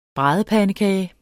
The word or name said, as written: bradepandekage